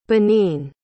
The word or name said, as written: benin